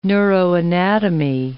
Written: neuroanatomy

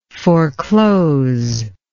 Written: foreclosable

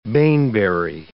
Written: baneberry

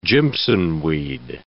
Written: jimsonweed

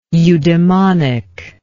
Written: eudemonic

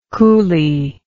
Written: coulee

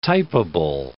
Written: typeable

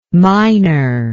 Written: miner

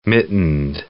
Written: mittened

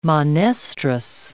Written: monestrous

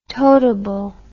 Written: totable

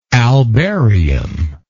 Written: albarium

